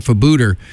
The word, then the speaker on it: Febueder: The band's name is spelled F-E-B-U-E-D-E-R, and according to their Facebook page, it's pronounced like Bermuda, but with an F.